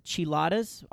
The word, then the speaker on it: Chiladas: Los, C-H-I-L-A-D-A-S. Chiladas? – Yeah, there you go.